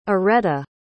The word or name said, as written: areta